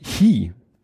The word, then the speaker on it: Qi: Laden über QI, das spricht man Hi, das Hi wie in Hecheln.